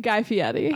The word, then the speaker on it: Guy Fieri: Guy Fieri.